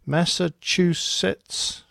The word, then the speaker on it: Massachusetts: How do you pronounce that? Massachusetts? That sounds good to me.